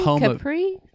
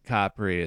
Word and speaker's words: Capri: Capri? – I think Capri is the Italian pronunciation. Capri is the anglicized one.